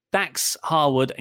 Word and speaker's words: Dax Harwood: but it cuts to the crowd, but not the crowd at ringside, the stands, where it's just Dax Harwood and Cash Wheeler. Did I say that right? – You did, yeah.